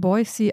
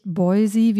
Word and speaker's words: Boise: Ich habe mich erkundigt bei Menschen, die die in Boise, Idaho geboren sind. Es wird also mit scharfem S ausgesprochen, nicht Boise, wie manche äh, vielleicht vermuten würden.